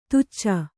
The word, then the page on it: ದೋಚು: dōcu